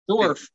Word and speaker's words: Dorff: How do you pronounce it? – Dorff. – Dorff. Stephen Blade Stephen. – He's not a little person. – Stephen Dorff. – Stephen Dorff? – Dorff. Dwarf. – Okay. It's the same as that Tim Conway character, Dwarf, who was a dwarf.